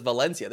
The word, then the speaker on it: Valencia: Actually, you know what? Valencia is actually said with a B. It's Valencia. – Valencia. – Here they pronounce their V's as B's.